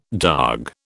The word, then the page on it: darg: British English